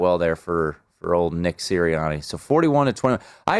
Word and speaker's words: Sirianni: Sirianni? Is that how you say his name? – Yeah, Nick Sirianni.